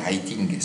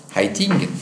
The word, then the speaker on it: Heyting: Haitink. Ça s'écrit H-E-Y-T-I-N-G. Haitink,